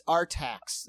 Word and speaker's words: Artax: I don't think it's A-R-T. It's Artax. That's how it looks. A-R-T-A-X.